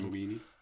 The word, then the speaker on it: Lamborghini: Und ein gewisser Bruce Wayne nutzt seinen Lamborghini. Lamborghini, wie kann man das so schlecht aussprechen? Lamborghini.